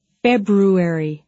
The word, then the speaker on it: February: Because it was, hard for some people to get that R in there. – And it's not hard. The word is February. – Right. So February. Is the correct pronunciation on this word.